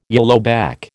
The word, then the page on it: yellowback: American English